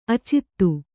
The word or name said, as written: ಆಚದು